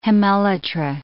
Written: hemelytra

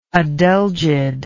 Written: adelgid

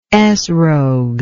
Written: esrog

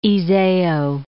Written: Iseo, Lake

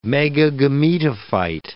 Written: megagametophyte

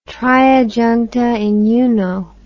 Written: tria juncta in uno

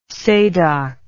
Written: sadoc

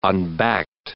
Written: unbacked

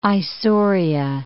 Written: Isauria

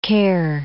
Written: Ker

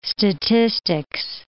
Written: statistics

